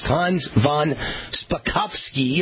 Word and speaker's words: Hans von Spakovsky: All right, we've got a guest, Hans von Spakovsky.